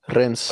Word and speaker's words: Rennes: Rens.